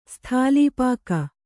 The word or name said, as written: ಸ್ಥಾಲೀಪಾಕ